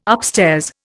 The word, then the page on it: upstairs: Indian